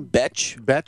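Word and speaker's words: Bethe: The Brazilian fighter, how do you pronounce the name? – Betch. Correa.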